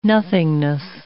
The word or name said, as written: nothingness